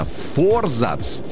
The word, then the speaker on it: форзац: Так вот, действительно, форзац считается правильным ударением. Но, вы знаете, словари сейчас спорят. Некоторые, те, что ратуют за произношение для комментаторов, для дикторов, для всех СМИ, они говорят, что форзац – это правильный вариант ответа.